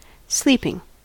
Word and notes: sleeping: American English

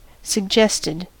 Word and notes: suggested: American English

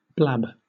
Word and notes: blab: British English

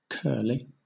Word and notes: curly: British English

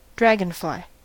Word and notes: dragonfly: American English

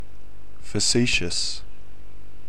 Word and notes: facetious: Australian English

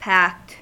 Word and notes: packed: American English

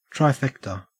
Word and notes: trifecta: Australian English

